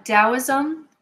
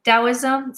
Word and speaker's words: Taoism: Taoism, sometimes pronounced Taoism, D or T, it depends on how you're translating from Chinese, but it's becoming increasingly popular because of Star Wars.